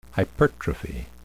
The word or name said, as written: hypertrophy